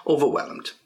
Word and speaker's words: overwhelmed: Okay, so remember the pronunciation over, that's one part, whelmed, yeah, and get d, whelmed, get the d at the end, overwhelmed.